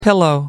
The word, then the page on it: pillow: American English